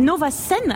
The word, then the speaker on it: Novacène: Donc je le disais dans le sommaire, le nom de cette expo, c'est Nova Scène. Alors Scène, ça s'écrit C-E-N-E. Qu'est-ce que ça veut dire, Nova Scène? – Nova Scène qui se termine un peu comme Anthropocène, et c'est justement l'ère qui va suivre l'Anthropocène selon James Lovelock.